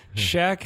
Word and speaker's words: Chaque: Shaq cashing. – Is that when you spell it with a Q? – Yes. C-H-A-Q-U-E.